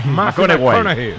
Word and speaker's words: McConaughey: McConaughey.